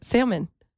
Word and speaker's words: salmon: You said it wrong. – I realized I say wrong. I said salmon. – Salmon. – Let's go. – Like there's an I in there.